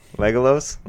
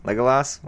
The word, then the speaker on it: Legolas: That's not how you pronounce that. – Legolos? Legolas? Whatever.